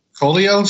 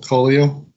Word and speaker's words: Coello: The artist is Ivan, I-B-A-N. I'm assuming it's pronounced Ivan. Mm. Not Ivan, but Ivan. – Mm. – And Colio, C-O-E-L-L-O, Colio.